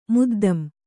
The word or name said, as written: ಮುದ್ದಮ್